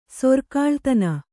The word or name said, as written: ಸರಿಗೆಳತಿ